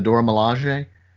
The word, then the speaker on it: Dora Milaje: And I didn't expect, and correct me if I'm saying this wrong, the Dora Milaje. – Yeah, you're right. That's correct.